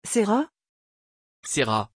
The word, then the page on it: cera: French